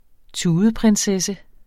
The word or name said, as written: tudeprinsesse